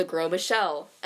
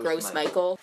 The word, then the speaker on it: Gros Michel: The one that we're going to be talking about, that this is going to focus on, is the Gros Michel. It's kind of a weird banana, especially considering the way it's spelled is GROSMICHEL. – Oh, so it looks like Gross Michael.